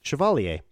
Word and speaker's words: Chevalier: How do you say that second one? – Chevalier. – Chevalier. – Mm-hmm. French. – It sounds very French.